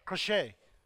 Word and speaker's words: crochet: Huh? Crochet. It's really crochet.